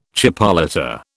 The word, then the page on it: chipolata: British English